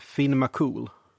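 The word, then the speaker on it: Fionn mac Cumhaill: För det stavas det då Fion Mackomhail. Men det ska uttalas Finn McCool.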